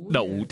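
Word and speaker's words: đậu (讀): Còn đậu thì sao? Ở đây không đọc là đọc, mà đọc là đậu.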